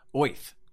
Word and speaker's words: Oerth: O-E-R-T-H. Do you pronounce it Earth? – O-Earth. – O-Earth. – Yeah. – Apparently, Gygax said it was actually pronounced Oith.